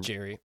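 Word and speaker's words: Gerry: Gary Spence agreed that him and... – Jerry. – Oh, my God. – It is spelled with a G, so I'll give you a little leeway.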